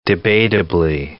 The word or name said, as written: debatably